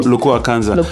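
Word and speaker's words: Lokua Kanza: DRC singer Lukau Kanza. Did I say that right? – Lukua Kanza. Legendary.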